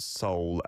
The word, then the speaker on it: Seoul: Miss Andriana is saying, in the Indonesian sphere, we pronounce Seoul as S-O-U-L. Well, that'll be how it is pronounced, like the same as Seoul in English to mean, you know, what's inside of you that might live on after you die.